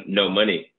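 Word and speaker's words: Know Money: And I remember I started my organization in 2014, No Money, it's spelled K-N-O-W.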